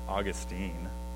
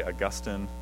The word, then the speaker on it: Augustine: On your handout, we have Ambrose of Milan, Jerome of Antioch, and this last one can be pronounced two different ways, but I'm going to be kind to my wife and pronounce it Augustine. You can also say Augustine of Hippo.